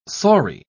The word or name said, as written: saury